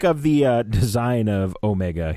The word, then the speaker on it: Omega: Like, it's Omega, okay, everyone? – Yeah. – Pronounce it correctly.